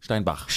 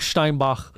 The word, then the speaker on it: Steinbach: Well, Steinbeck is Stony Steinbach. – Brook. – Steinbach. – Steinbach. – Yeah, you gotta pronounce it correctly.